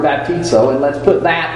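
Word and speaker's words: baptizo (βαπτίζω): When we try to pronounce that term, it typically is baptizo.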